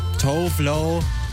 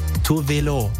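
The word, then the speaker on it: Tove Lo: Das ist für viele Leute Tovlo, aber eigentlich spricht man sie Tuvelo.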